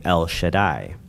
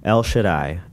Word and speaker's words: El Shaddai: the name El Shaddai. So, and we're going to cover that one next. El Shaddai.